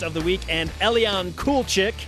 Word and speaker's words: Kulczyk: It's spelled K-U-L-C-Z-Y-K, so I get that, but it's pronounced cool chick.